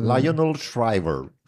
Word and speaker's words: Lionel Shriver: Lionel Shriver.